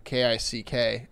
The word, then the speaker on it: Kik: I thought there was I read now here seeing KICK, but there's another like chat app called like Kick, KIK.